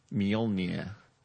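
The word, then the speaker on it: Mjölnir: The correct pronunciation is Mjolnir. – So the difference between the two is almost imperceptible to the human. No, there's a y. There's a mjol.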